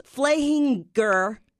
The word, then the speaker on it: Flehinger: So it's Flehinger is the German pronunciation. And anybody from this town in Germany, Flehingen, was a Flehinger.